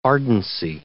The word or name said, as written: ardency